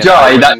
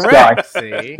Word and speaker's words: Jai: But if you read it as Courtney Jai, that's correct because it's an Indian name. – Right. – So it would pronounce Jai.